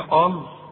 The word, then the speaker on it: قال: قال اولا اللام دائما مرققه الا في لفظ الجلاله، فتيجي هنا وتقول لي قال، قال ده ايه؟ قال، شوف قال رق.